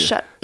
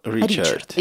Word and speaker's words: Ричард: Ришар. – В том-то дело, что во Франции его произносят как Ришар, но вот по всему миру Ричард.